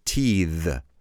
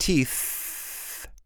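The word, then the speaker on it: teethe: Now listen to the way we pronounce the verb teeth. It's not teeth. It's pronounced teeth. So I use my voice right at the end of that verb, a little bit of voice to pronounce that last sound.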